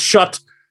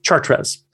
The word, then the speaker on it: Chartres: and Chut, although it's pronounced Chut, even though they mean, I look at it and I say Chartres.